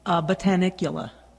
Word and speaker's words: Botanicula: Yeah, it was called Binicula or Binocula or something like that was the last release. Yeah. – I don't know if I trust you pronouncing it. – Tony is not our king of pronunciation. – Yeah, and we've given him a hard time on many episodes. It's actually Botanicula.